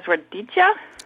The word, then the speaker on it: radicchio: and she used to grow a lot of vegetables in her garden, and one of them was radicchia. – Yes. – Radicchia. – Lenticchia. – Yeah, with an A on the end is how she pronounced it.